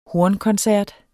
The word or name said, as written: hornkoncert